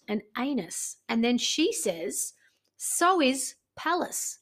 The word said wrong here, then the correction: phallus: Phallus. – Phallus. – Phallus, yeah. – Well, it's got a P there. – Yeah, it's a silent P.